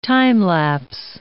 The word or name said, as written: time-lapse